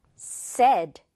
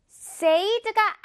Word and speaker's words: said: 하지만 여기에 지금 이렇게 s가 오면 이 발음은 어떻게 되냐면 said, 이렇게 발음이 된다는 사실. said가 아니라는 거 꼭 주의를 해야 합니다. 정말 많은 친구들이 said라고 과거 항상 이렇게 잘못 발음하는데 우리 친구들은 이제 오늘부터 안 그럴 수 있겠죠?